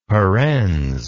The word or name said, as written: parens